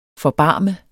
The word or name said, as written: forbarme